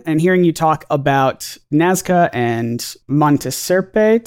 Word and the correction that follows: Sierpe: Monte Sierpe, the sort Sierpe. Of, in Spanish, the vowels don't blend like that, like they do in English, so Sierpe.